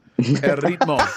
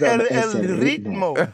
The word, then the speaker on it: ritmo: El ritmo. Bunny, es el ritmo. El ritmo. – Oh, es el ritmo. – Okay. Yeah. Roll your R.